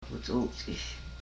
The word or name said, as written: ku chuchish